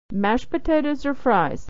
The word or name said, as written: or fries